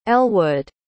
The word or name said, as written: Ellwood